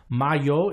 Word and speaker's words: Majo: They said the Y in M-A-J-O, Mayo, is like Juventus, which makes sense.